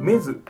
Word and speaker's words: MEDU: はい、この漫画は無料コミックサイト MEDU メズと読むらしいんですけど、発表された作品をまとめたものです。